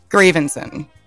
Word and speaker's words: Gravenson: I mean, I didn't feel like there was a good, because it sounds the way it's spelled. Yeah, but it's fine. I think you made it sound classier. Gravenson. – Gravenson. – Like I liked the way you were saying it, but officially it's Gravenson.